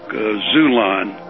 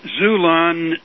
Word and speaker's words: Xulon: Well, Zulon is a so-called Christian publisher and printer. – How do you spell that? – It's the same X in Xerox or xylophone. Okay. X-U-L-O-N. – Very good. X-U-L-O-N.